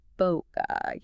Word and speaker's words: bokeh: The class notes say it's okay to say bokeh. – Or bokeh, yeah, both seem pretty common.